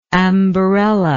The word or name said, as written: ambarella